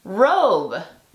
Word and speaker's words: robe: La E le dice a la O que diga su nombre. Entonces se pronuncia robe.